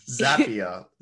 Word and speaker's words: Zapier: Zapier. Zapier like happier.